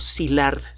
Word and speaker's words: Szilard: Bueno, cuando eh, algunos físicos, eh, de manera muy importante, Leo Szilard, se escribe S-Z-I-L-A-R-D, un nombre crucial en, esta historia.